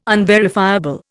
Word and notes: unverifiable: Indian

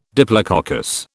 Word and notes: diplococcus: British English

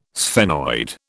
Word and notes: sphenoid: British English

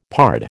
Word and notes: pard: American English

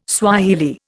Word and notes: Swahili: Indian

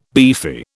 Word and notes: beefy: British English

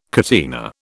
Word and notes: catena: British English

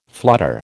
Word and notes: flutter: American English

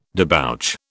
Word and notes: debouch: British English

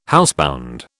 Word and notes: housebound: British English

